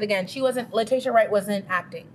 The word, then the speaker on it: Letitia: Here's the thing: if, if someone were to tell me it was Letitia, Letitia, that's how you say her name.